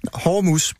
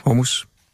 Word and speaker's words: Hormus: Hormus. Bare tænk på hår og en mus. – Ja. – Hormus.